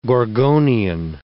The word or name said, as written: Gorgonian